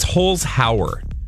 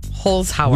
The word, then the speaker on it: Holzhauer: Also, it's Holzhauer, James. – Holzhauer.